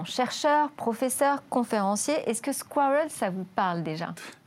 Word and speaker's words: SQRL: Et cela se prononce squirrel dans mon bon anglais, qui veut dire écureuil.